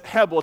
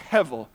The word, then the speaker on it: hebel: There's a word written on your Bible that says hebel. It's actually pronounced hevel.